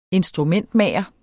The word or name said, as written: instrumentmager